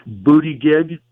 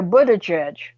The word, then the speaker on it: Buttigieg: Note how I pronounce it, booty gig.